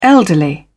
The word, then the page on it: elderly: British English